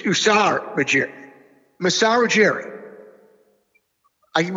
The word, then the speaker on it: Ujiri: It's Ujiri, right? Masai Ujiri? – Yeah, right. – That's how you pronounce it? – That was good.